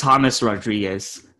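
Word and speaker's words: James Rodríguez: Is it James Rodriguez? James Rodriguez? What's the correct pronunciation of his name so that we can get it right, you know, as we hopefully have him locked up here shortly? – It's James Rodriguez.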